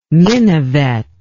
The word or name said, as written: minivet